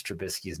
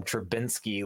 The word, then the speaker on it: Trubisky: I'm like, dude, it's like biscuit, dude, like Trubiscuit. And he's like, is it biscuit? I'm like, no, man, that's just how you like pronounce his name. It's like a biscuit, like Trubisky and Trubiscuit.